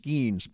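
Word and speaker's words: Skenes: I think it's pronounced Skeens. Paul Skeens. – Yes. The guy from Pittsburgh, yeah.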